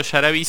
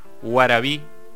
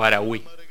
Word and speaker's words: arawí: Y bueno, entonces lo que nos toca ahora, que creo que se pronuncia no tanto yarabí, sino guarabí o arawí.